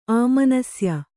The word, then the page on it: ಆಮನಸ್ಯ: āmanasya